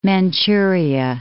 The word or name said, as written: Manchuria